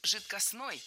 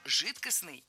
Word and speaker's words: жидкостный: Да, итак, прозвучали варианты «жидкостный» и «жидкостной». А какой из них верный? Вариант «жидкостный» является литературной нормой. Ударение исходного существительного «жидкость» в нем сохраняется, но окончание должно быть «ый». «Жидкость» – «жидкостный».